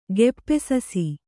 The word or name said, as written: ಗೆಪ್ಪೆಸಸಿ